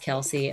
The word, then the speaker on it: Kelce: NFL star Travis Kelce. Am I saying his name right? – Yep. You're saying it right.